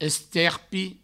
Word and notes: esterpi: Provençal

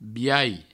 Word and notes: biais: Provençal

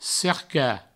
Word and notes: cercar: cerca; provençal

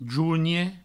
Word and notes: jonhe: jougne; Provençal

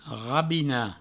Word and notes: rabinar: provençal; rabina

